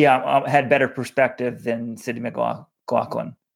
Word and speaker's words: McLaughlin: And for the record, guys, it's McLaughlin. We received an email from a New Jersey track coach who said Sydney's dad is a track coach. They always say McLaughlin, and no matter how it's spelled. And John pointed out he found Sydney's signing on New Balance, and she pronounces her last name McLaughlin.